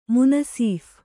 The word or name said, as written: ಮುನಸೀಫ